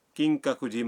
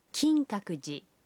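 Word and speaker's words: کنگ ککوجی (金閣寺): مثلاً آپ کیوتو کے کنگ ککوجی مندر جانا چاہتے ہیں. مندر کے نام کا تلفظ ہے کنگ ککوجی. کنگ ککوجی.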